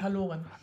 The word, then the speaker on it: Haloren: Nee, Haloren. Das wäre jetzt auch intuitiv das gewesen, wie ich es ausgesprochen hätte, aber es heißt tatsächlich Haloren.